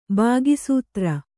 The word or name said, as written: ಬಾಗಿಸೂತ್ರ